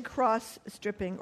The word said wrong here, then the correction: striping: Stripping or striping? – Striping. – Thank you. The pedestrian cross-striping machine. I was mispronouncing it, so no wonder you got it wrong.